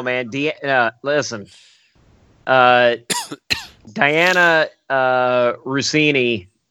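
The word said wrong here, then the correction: Dianna: It's Deanna now. – Yeah. – Oh, it is Deanna now. Okay. Well, it's spelled like Diana with an extra N.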